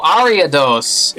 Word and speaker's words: Ariados: Put Eridos in Bill's PC. Now we can write Eridos into our um, into our list. Oh, we did not spell that properly. Let's look in the Pokedex. Um, Oh, Ariados.